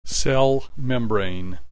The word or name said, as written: cell membrane